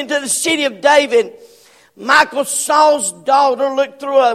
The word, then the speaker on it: Michal: I've always pronounced it Michael, but Bible words call her Michal.